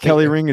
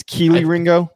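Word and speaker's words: Kelee Ringo: It's not Kelly Ring. It's Keely Ringo.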